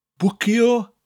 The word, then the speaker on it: bwcio: Bwcio is one of those Welsh words that comes from an English word. It means to book or to reserve, and it's spelt B-W-C-I-O. You get that slight oo sound with a W, bwcio. And it's followed by stafell, which means room, spelt S-T-A-F-E-L-L.